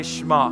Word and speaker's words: Shema: Everybody say Shema. And so that's how you pronounce it. It's the Shema.